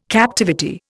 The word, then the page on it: captivity: Indian